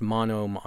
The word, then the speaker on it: monome: I don't know if it's pronounced monom or monomy. – I heard it pronounced monome on a YouTube how-to video.